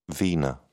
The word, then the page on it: vena: British English